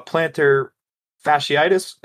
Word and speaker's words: plantar fasciitis: And, you know, the dude just came off that plantar fasciitis.